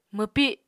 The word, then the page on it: mwpi: Apatani